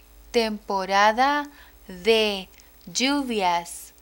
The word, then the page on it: temporada de lluvias: spanish